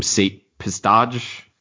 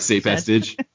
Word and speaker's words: Sate Pestage: It's going to introduce other characters like, how do you pronounce his name? Sate Pestage. – I have enough audio books that I've heard his name. – All right, Sate Pestage, you know, and other characters like that.